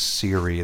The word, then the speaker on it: Siri: No, It's from SRI, International Artificial Intelligence Center, the SRI. SRI means Siri. That's where it came from. If you were to pronounce it, that's how you would do it.